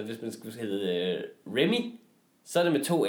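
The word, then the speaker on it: Remee: For han hedder jo R-E-M-E-E. E, -E, Remy. Ja. Alle udtaler det Remy hvilket vil være R-E-M-M-Y. Det, det er Remy.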